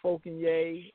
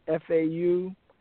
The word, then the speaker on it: Fauconier: My website is Friends for Fauconier, and my last name is spelled Fauconier.